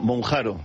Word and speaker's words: Mounjaro: Bueno, Mounjaro.